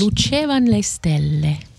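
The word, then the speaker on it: E lucevan le stelle: And, you're proper Italian. Pronounce it properly for me once. – Le Lucevan Le Stelle.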